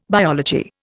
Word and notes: biology: Indian

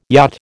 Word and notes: yacht: American English

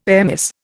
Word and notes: airmiss: Indian

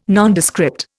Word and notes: Nondescript: Indian

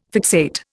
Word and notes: fixate: Indian

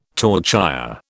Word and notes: torchère: British English

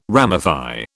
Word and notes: ramify: British English

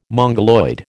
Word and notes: Mongoloid: American English

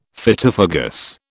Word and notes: Phytophagous: British English

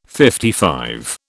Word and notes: LV: British English

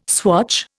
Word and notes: swatch: Indian

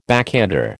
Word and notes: backhander: American English

